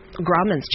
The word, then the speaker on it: Grauman's: 99 years ago, Grauman's Chinese Theater, did I say it right? – Yes.